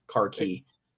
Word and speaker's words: khaki: Or, or khaki, the... as it's pronounced in the Commonwealth, khaki.